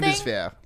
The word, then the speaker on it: Bundeswehr: It says Bundeswehr or something. – Bundeswehr.